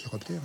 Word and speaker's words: chiroptère: Les chiroptères, hein ? – Oui, je pense que c'est ça. Vas-y. – Les chiroptères. – L'ordre des chiroptères, ça s'écrit C-H-I. Et ça se prononce bien chiroptère.